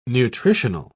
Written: nutritional

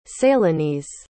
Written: Ceylonese

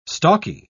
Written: stalky

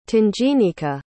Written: tanginika

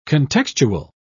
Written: contextual

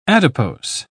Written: adipose

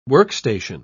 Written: workstation

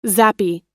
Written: zappy

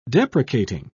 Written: deprecating

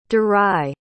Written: daray

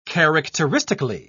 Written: characteristically

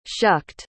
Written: shucked

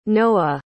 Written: noah

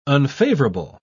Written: unfavorable

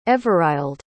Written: everild

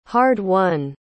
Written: hard-won

